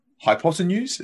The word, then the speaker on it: hypotenuse: hypotenuse? Is that how I say it? – Yeah, that's how you say it.